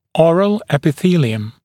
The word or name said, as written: oral epithelium